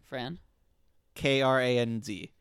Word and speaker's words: Kranz: Deceptively hard to pronounce. – Fran. – K-R-A-N-Z. – Kranz. – That's completely... – Fran Kranz. – Yes.